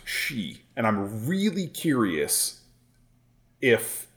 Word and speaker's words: Sidhe: So the word is spelled S-I-D-H-E. – Yeah. – And as far as I know, that word is pronounced she. – She? – She. – Wow. – But I have heard people pronounce it Sith. – What? I would guess side.